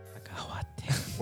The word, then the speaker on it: cacahuate: Cacahuate? – Cacahuate. – H is silent. – Cacahuate.